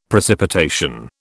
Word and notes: precipitation: British English

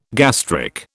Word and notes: gastric: British English

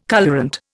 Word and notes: colourant: Indian

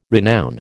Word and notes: renown: American English

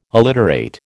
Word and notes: alliterate: American English

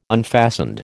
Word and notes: unfastened: American English

